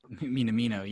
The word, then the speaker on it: Minamino: like Orihi and Minamino, if I said that right. – Minamino, yeah.